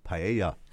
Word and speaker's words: paella: I know that it's actually pronounced paella. – So I've just been saying that wrong the whole morning. – The L's are silent, but, you know, it's tough. It's paella. It's paella. Hard to say.